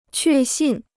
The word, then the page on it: 确信: què xìn